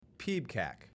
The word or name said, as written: PEBCAK